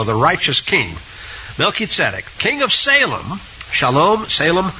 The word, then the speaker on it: Melchizedek: but I like Melchizedek.